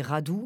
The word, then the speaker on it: Radu: Je connais juste mon nom de famille roumain qui est Radu. Mais après, des Radu, il y en a 36 000 hein, en Roumanie. Donc R-A-D-U, d'ailleurs, ça s'écrit.